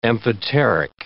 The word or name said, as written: amphoteric